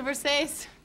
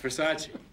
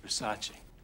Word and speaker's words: Versace: Thanks. It's a Versace. – It's Versace. – What? – It's pronounced Versace.